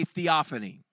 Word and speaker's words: theophany: Can everybody say theophany? Now, let's all spell it together. T-H-E-O-P-H-A-N-Y.